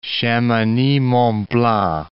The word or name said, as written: Chamonix-Mont-Blanc